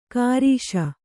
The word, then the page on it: ಕಾರೀಷ: kārīṣa